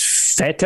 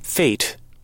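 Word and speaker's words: fête: So originally I thought it was fete. Because I thought I had never seen it. Uh-huh. Somewhere I heard it pronounced feet. – Okay, so that's in Google. It says it's pronounced F E with the little triangle above it, whatever that symbol is called. – Yep. – Here's what it sounds like. – Fate. – Fate. – Oh, fate.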